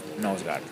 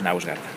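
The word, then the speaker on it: Knausgård: Es que la pronunciación también varía dependiendo del idioma. Hace poco una chica de Glasgow me decía que ella lo pronunciaría como Karl of Nausgard. Bueno, en España yo creo que diríamos Nausgard.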